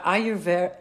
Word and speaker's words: Ayurveda: Ayurveda.